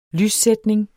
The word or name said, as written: lyssætning